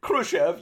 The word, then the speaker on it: Khrushchev: Nixon, of course, naturally, because Nixon and Soviet leader Khrushchev, and I hope I'm pronouncing that right. – That sounds right. Why not? – Khrushchev, yeah.